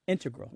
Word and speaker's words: integral: Yeah, but both correct, just to clarify here, both correct, but the mathematicians and math-using scientists are more likely to put, say, integral with the stress on the first syllable.